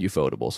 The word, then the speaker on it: Ufotable: I think it's Ufotable. – Oh. – I'm pretty sure. – No, I never I always read it as UFO table, but if you look at it, they don't capitalize anything differently from my knowledge.